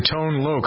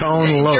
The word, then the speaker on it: Tone Loc: Number four during this week in 1989 belonged to Tone Loc. How did I do, Jennifer? Is that right? – Very good. Yes. – Tone Loc.